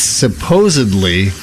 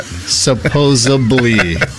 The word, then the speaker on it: supposedly: It's supposedly, not supposably.